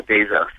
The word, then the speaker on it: Bezos: Yeah, yeah, Bezos.